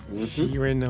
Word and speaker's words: Sheeran: Sheeran, though.